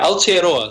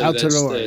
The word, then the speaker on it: Aotearoa: Now I'm going to need your help pronouncing this, but Aotearoa? – Yeah, Aotearoa. So Aotearoa, that's Te Reo Māori, the official name for New Zealand prior to Abel Tasman naming it after the city in Holland.